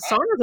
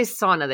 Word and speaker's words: sauna: for the, and they don't say sauna, they say sauna.